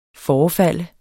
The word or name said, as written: forefalde